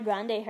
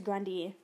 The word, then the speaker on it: Grande: Like apparently Ariana Grande, her last name's Grundy. But everyone just pronounces it wrong.